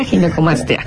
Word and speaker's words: ginecomastia: Pues muchas gracias, y sí, definitivamente creo que es una, para empezar la palabra, le decía, ¿cómo se pronuncia? Ginecomastia, ginecomastia. – Sí, exacto, es ginecomastia. – Ginecomastia, exacto. Okay.